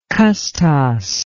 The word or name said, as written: custos